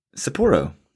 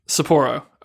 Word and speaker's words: Sapporo: Is it Sapporo or Sapporo? I need to get a handle on that pronunciation before the Olympics next year. – Whoa, as a beer drinker, I'm kind of shocked you don't know that. Sapporo.